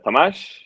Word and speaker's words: Tamas: Okay, so I'm Tamas from Hungary.